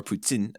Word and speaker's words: poutine: So poutine, or poutine, as it's pronounced in Quebec, is fries, cheese, and gravy.